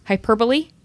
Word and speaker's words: hyperbole: She and I do burlesque together, and she's a really cool, very open-minded art person, but someone who's not really given to, I'm going to say it wrong, Liz, hyperbole? – You said it exactly right. – Good job, me. Hyperbole. It's not hyperbole, no matter how much I want to say it that way.